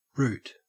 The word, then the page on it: root: Australian English